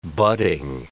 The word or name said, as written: budding